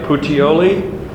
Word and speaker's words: Puteoli: Did I pronounce it right? Puteoli? Need to talk in Italian. Puteoli. There we go. Put the right emphasis on the right syllable.